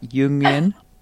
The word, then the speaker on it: Jungian: So you've got the perfect balance of your parents, the transformative characteristics, they've been Jungian, how do you say that? Jungian? – Everyone kind of says it a little bit differently, but yeah, Jungian is fine.